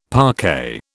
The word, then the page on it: parquet: British English